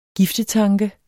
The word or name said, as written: giftetanke